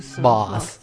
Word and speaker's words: bus: Allora, loro, il bus. – Boss. – No, loro dicono boss.